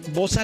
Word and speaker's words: Bosa: Bosa, La Concepción, ¿sí? ¿Lo dije bien? – Sí, exacto, sí.